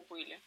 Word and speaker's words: пыли: Ну, пыль, там нету в пыли, есть только о пыли. – Ну, в пыли, там ударение меняется.